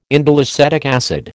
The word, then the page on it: indoleacetic acid: American English